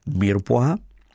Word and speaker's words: mirepoix: Jmenuje se to česky, když to vyslovím teda takhle, když to vyslovím po francouzsku, tak se to jmenuje mirepoix, ale já tomu říkám polévková bonička.